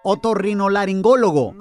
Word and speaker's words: otorrinolaringólogo: Es otorrinolaringólogo.